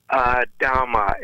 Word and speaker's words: Adhama: Adhama. It's like Adam with an A at the end.